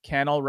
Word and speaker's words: Cannell: I'm going to tell you how I remembered that is because he told me Cannell rhymes with channel, and so now I never have forgotten it.